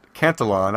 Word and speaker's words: Cantillon: Why, yes, he did just correct your pronunciation of Canton. Cantillon. I can't even say it. That's uh, it's Cantillon.